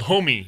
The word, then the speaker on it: Homey: it's called Homey. It's just like it's spelled H-O-M-E-Y.